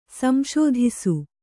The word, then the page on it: ಸಂಶೋಧಿಸು: Kannada